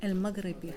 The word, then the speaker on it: Elmaghribi: So what does Samy Elmaghribi, am I saying it right? – Elmaghribi. – Samy Elmaghribi. – From the Maghreb.